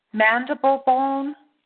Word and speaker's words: mandible: So is this as to the mandible bone? See if I'm pronouncing that right. Mandible bone? – Yes. – Yes, okay. Wow. All right. Crazy, eh? Okay, so mandible, that's spelled M-A-N-D-I-B-L-E.